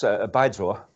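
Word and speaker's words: bai zhuo (白左): Can you pronounce it properly for us, first of all? – That's bai zhuo. – Okay. – That's the tone.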